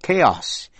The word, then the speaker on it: chaos: Cũng phát âm là K trong những chữ mượn của tiếng Hy Lạp như chaos, C-H-A-O-S là hỗn loạn. Chorus, C-H-O-R-U-S là đồng ca hay là điệp khúc. Choral, C-H-O-R-A-L là được hát bởi một ban hợp xướng.